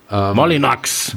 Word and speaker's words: Molyneux: Molyneux vielleicht ein bisschen weniger französisch ausgesprochen. – Molinox.